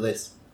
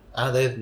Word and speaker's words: dead: dead, dead o Des. – Dead. Ah, Dead.